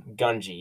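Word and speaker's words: Gungi: it's pronounced Genji, correct? Or Gunji? – Gunji? – Gunji, yes. Gunji, who was a Jedi, which is the first Wookiee Jedi that we know of.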